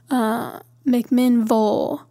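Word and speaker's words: McMinnville: but most names in the South, or at least in Tennessee, that have a ville at the end of it, it's Vole. So there's like Shelby Vole, McMinn Vole, like, I don't know why it's pronounced like that, but it is.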